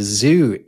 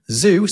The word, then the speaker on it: Zoo: Because it's where the zoo is. But in German, zoo, spelled Z-O-O, is pronounced Zoo, so not zoo.